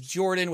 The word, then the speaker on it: Jordan: My name is Jordan with a silent PH in the middle, and I am joined by two uh, awesome, awesome people.